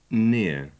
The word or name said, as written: near